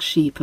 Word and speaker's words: sheep: Some people really have difficulty in distinguishing between the E sound that you hear in sheep and the I sound that you hear in ship. Sheep. Ship. Now, if you struggle with hearing and pronouncing this particular difference, a really helpful exercise is to practice what they call minimal pairs.